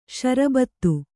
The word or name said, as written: ಸಾರಭೂತ